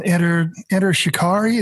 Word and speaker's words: Enter Shikari: Enter Shikari, is that how you pronounce that? – Yeah.